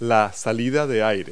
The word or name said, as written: air vent